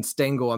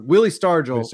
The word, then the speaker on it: Stargell: I just realized I was mispronouncing Willie Stargell from Pittsburgh. I kept saying Stengel. I'm thinking of Casey Stengel. Yeah. Willie Stargell.